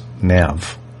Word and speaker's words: Neve: Her first name, Neve, is her mother's maiden name and is pronounced Neve. It means snow in Dutch.